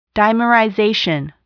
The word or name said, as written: dimerization